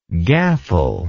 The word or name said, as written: gaffle